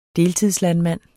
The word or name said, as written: deltidslandmand